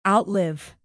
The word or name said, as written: outlive